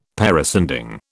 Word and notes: parascending: British English

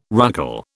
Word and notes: ruckle: British English

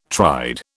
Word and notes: tried: British English

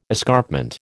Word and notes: escarpment: American English

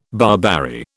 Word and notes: barberry: British English

